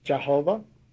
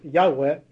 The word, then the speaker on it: Yahweh: By the way, that's not the correct pronunciation. It's Yahweh in the Old Testament. The Jews did not use vowels in their alphabet, so we really do not know how they pronounce that name, if it's Yahweh, but it was not Yahovah, as they say today.